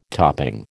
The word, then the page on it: topping: American English